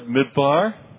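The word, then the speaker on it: Midbar (מדבר): There are two words in Hebrew that look alike and are spelled alike. – 有两个希伯来字看起来一样，而且发音起来也差不多。 – One is the word Midbar. – 第一个字就是 Midbar，就是旷野的意思。 – And the second is the word Midaber. – 另外一个字就是 Midaber，就是上帝说的意思。 – It's spelled the same way in Hebrew. – 在希伯来字，它其实是它的那个发啊、就是拼法是一样的。 – But they have completely different meanings.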